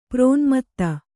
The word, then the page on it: ಪ್ರೋನ್ಮತ್ತ: prōnmatta; kannada